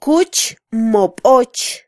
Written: kuch mo boch

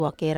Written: wakera

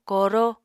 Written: koro

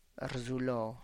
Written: rrzulo

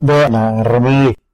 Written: nde na rremed

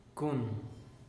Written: cun

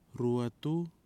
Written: RUETU

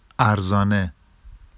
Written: arzɑne

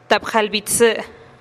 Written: tap jalih bi tsï

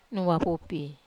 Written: wapupi

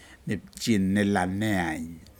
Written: nipji nelaneayn

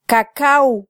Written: kakaw

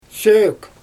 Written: ʃøøk

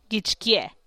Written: gitx kye

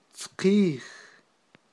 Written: tzqij

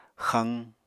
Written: xaq

